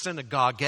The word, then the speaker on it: synagoge: It's interesting that the very word synagogue, or really synagoge is how you would pronounce it, as an action, as a verb, assembling together.